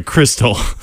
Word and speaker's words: Crystal: If she's repping the local Louisiana brands, she's likely carrying Tabasco, Cristal, or Louisiana hot sauce. – Cristal? – Sorry, Crystal. – Oh my God, really? Man. You just pronounced Crystal as Cristal now?